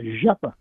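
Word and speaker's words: Rzeppa: Well, when you got a big vehicle, and it has to be very accurate, the type of a joint is called a Zeppa joint. That's what it's pronounced, but it has a C.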